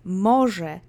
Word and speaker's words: może: Let's say może together three times. And same as before, accent is on the first syllable.